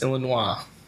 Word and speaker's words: Illinois: It's pronounced Illinois.